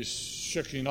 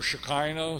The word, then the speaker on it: Shekinah: That later on, a noun was made out of it, the Shekinah or Shekinah, sometimes it's pronounced,